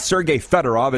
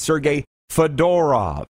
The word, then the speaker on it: Sergei Fedorov: They said you need to put the emphasis on the middle syllable. It's not Sergei Fedorov, it's Sergei Fedorov.